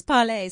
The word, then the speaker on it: Parley: So I've been pronouncing it wrong. It's Parley.